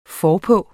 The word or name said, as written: forpå